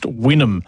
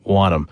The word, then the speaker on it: Wynnum: In fact, pronounced Wynnum, not Wynnum. Yeah, when you try and pronounce places outside of the state you live, you just sort of cross your fingers sometimes.